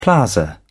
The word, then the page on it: plaza: British English